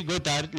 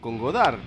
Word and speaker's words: Gotthard: Que es como un juego de palabras con Got Art. – Se escribe G-O-T-T-H-A-R-D, Hard al final y claro, Got con doble T.